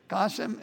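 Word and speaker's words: Goshem (גשם): And what's the word Geshem mean? Goshem, I'm saying it wrong, Goshem, rain.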